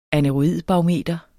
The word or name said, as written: aneroidbarometer